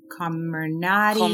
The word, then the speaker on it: Comirnaty: So so just to clarify for the audience and for myself, the Pfizer shot, which we were talking off air how to pronounce it, is Comirnaty.